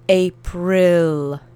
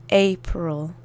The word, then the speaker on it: April: I tu litera I, po prostu pamiętajcie, że trzeba wymówić jak nasze I, czyli April, I-I-Ill, ok? No i ostatnia rzecz to L na końcu, to się wymawia miękko, podobno do rosyjskiego L, L, L.